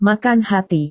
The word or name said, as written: makan hati